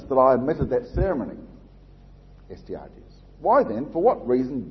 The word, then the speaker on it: Astyages: Astyages.